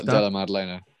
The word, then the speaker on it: Della Maddalena: Is that how you say his name? Or is it like Maddalena? – Della Maddalena. Yeah, Jack Della Maddalena. – Oh, so no, it looks fancy, but you just say it exactly how it sounds.